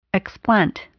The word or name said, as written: explant